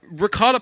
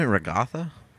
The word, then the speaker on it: ricotta: Isn't it regatha? What? That's how I pronounce it. Regatha cheese? – Ricotta.